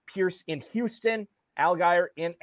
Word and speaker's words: Allgaier: He wants to know, do Damian Pierce and Tyler, and I'm going to butcher this, Farrell, is it Allgaier or Allgaier? – I think the first time right. – Allgaier.